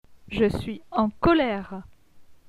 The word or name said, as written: Je suis en colere